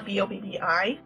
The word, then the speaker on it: Bobbi: Um, my name is Bobbi Anderson, and my just spell it B O B B I.